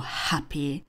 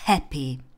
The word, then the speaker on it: happy: A u toho slovíčka family, podobně jako třeba u slovíčka happy nebo dance, apple, se mě studenti často ptají, proč vyslovuju happy, když oni to znají jako happy. Proč říkám dance, když oni to jasně znají jako dance. A odpověď je jednoduchá, oboje je správně. Záleží jenom na tom, jestli se víc přikláníte k britské výslovnosti nebo k americké výslovnosti.